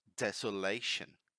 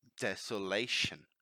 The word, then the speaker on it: desolation: Desolation. Desolation. Mind the pronunciation here. It's not desolation. It's not like a Z sound. It's desolation, right?